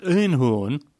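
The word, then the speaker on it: Eenhoorn: A philosophy he's now bringing to AZ Alkmaar, whose general director, Robert Urnhorn, and that's exactly how it's pronounced, by the way, used to play baseball for the Yankees and the Anaheim Angels, or California Angels, isn't it, in the 1990s.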